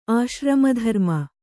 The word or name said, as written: ಆಶ್ರಮಧರ್ಮ